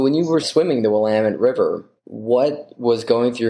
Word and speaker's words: Willamette: Willamette River. – Yeah, it rhymes with damn it.